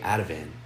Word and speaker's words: Ativan: And Danny, I noticed she takes something called Avitan. I don't know if I'm saying that right. – Ativan. – Ativan? – Yeah.